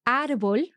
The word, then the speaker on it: árbol: So, for example, árbol, tree, has an accent. – And the reason for that is because it ends in L, then quite often words that end in L will have their accent on the last syllable. They will be aguda, like natural. – Eso es. – But if we want to force the accent or force the stress elsewhere, then we can use the accent, the written accent to force that.